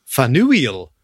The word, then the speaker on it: Faneuil: It's got a very unusual spelling to the point where I had to check how you pronounce it because it's spelt F-A-N-E-U-I-L. So I thought it might have been French like Faneuil. Or something. – But it's Faneuil.